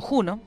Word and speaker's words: Juno: Estamos hablando de Juno, o Juno, como se lee y se escribe,